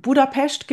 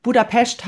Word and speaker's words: Budapest: Die Stadt, sie sagen immer, Budapest hat nichts mit der Krankheit Pest zu tun. Und das S, ein alleinstehendes S wird wie ein Sch ausgesprochen. Auf jeden Fall, als wir dann in Budapest gewohnt haben,